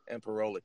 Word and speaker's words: Imperioli: Imperioli.